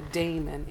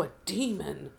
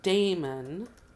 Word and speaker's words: daemon: And just in case people don't know what a daemon is, because a lot of people could say, "Oh, a demon," but it's daemon. – DAE, right? – No, it's DAIMON. – Oh, DAI. So I've heard it pronounced diamond too. – But it can be DAEMON. That's a variant.